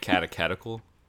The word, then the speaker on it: catechetical: Catechetical.